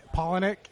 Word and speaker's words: Palahniuk: How do you say his name? – Palahniuk. Yeah.